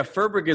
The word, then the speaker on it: Furberg: So, yeah, Furberg is what that's how we pronounce it now, I guess, within my family. Uh-huh. In German, it's supposed to be pronounced Feuerberg, which is like I don't know where it got lost.